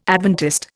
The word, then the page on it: Adventist: Indian